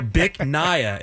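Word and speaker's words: Bích Ngà: And the name of the restaurant, I'm just going to spell for you. B-I-C-H-N-G-A. So, Bic Naya is how you say what? – It.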